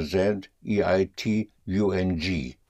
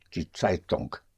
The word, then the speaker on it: Zeitung: Spelled Z or Z, E-I-T-U-N-G. Die Zeitung. And the N-G in German, unlike in English, the N-G is pronounced. Zeitung. The G comes out, die Zeitung.